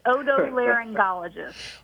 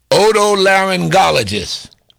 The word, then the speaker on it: otolaryngologist: It's otolaryngologist. – Otolaryngologist.